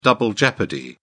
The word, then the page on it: double jeopardy: British English